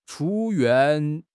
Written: chu1 yuan4

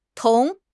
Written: 铜

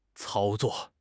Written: 操作

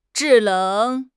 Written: zhi4 leng3

